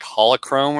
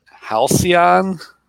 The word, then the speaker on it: Halcyon: I thought it looked like holochrome or something. – Yeah. – Halcyon, what you said?